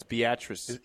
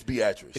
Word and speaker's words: Beatrice: And they would say, "Ooh, and Beatrice loses to Waverly." I'm like, no, dude, get it right. You're from the state. Say it right. It's Beatrice. – It's Beatrice. – It's never not been Beatrice.